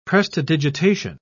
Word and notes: prestidigitation: British English

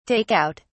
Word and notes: take out: British English

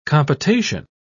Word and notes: compotation: British English

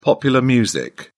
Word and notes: popular music: British English

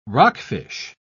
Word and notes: rockfish: British English

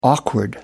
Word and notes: awkward: American English

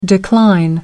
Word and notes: decline: British English

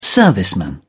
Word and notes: serviceman: British English

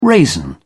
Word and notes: raisin: British English

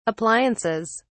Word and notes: appliances: British English

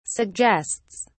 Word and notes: suggests: British English

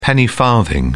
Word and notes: penny farthing: British English

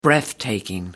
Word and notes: breathtaking: American English

